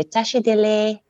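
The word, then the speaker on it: Tashi delek: So, although it's spelled delek, the K is, we kind of drop the K. So we say Tashi dele.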